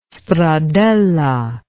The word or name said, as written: stradella